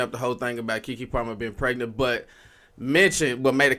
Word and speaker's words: Keke Palmer: I'm saying it right, Keke Palmer.